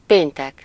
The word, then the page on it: fredag: /ˈfreːdɑːɡ/; /ˈfreːda/